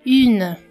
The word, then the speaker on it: une: Une fille para falar in, esse som inicial é i. É como se você estivesse falando um i, mas com os lábios arredondados como se fosse um u. Ou seja, você pronuncia i, mas os lábios são arredondados.